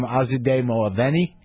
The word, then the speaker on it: Azadeh Moaveni: Please welcome Azadeh Moaveni.